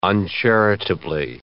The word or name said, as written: uncharitably